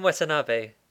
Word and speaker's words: Watanabe: Say these names. If I can't pronounce them correctly, you should step in. – Ken Watanabe. – Well, that's not that different to what I just said. – Watanabe. – I got Ken right.